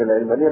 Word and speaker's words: العلمانية: فكلمة العلمانية مش مأخوذة من العلم، مأخوذة من العالم، العالم، العالم. واضح؟ فأدق النطق ان احنا نفوت عليهم الفرصة، وبدل ما نستعملها بالكسر العين اللي بيخدعهم ناس وبيظلموهم من العلمانية مأخوذة من العلم. لا، هي مأخوذة من كلمة العالم.